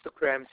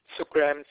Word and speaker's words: Sookram: Yes, Sukram Singh. – I'm sorry? – Sukram Singh. – How do you spell that? – S-O-O-K-R-A-M. That's the first name. The last name is S-I-N-G-H.